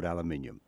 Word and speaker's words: aluminium: Sometimes called aluminium. – Aluminium, yes. Yep. Aluminium across the water. On this side, aluminum. Yeah, it's one of those elements that still is not spelled the same depending on where you are.